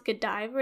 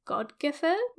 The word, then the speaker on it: Godiva: And, like, it's not technically pronounced Godiva, it's Godgiver, which is, like, old English.